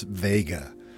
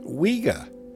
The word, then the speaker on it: Vega: Many people mispronounce it as Vega, but it's actually an old Arabic word, originally pronounced Wega, which means a falling eagle.